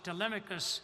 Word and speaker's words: Telemachus: And as he lived in those areas, and his name was Telemachus, or Telemachus in an anglicized pronunciation.